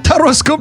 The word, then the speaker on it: Тароскоп: А вы слышали, как правильно произносится рубрика? Это вам не Таро Пипидастрова. Это Тароскоп на радио Энерджи.